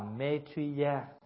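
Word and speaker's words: Maitreya: Bởi vì Di Lặc á là phát âm từ cái tiếng Phạn, nếu nói cho đúng á là Maitriya.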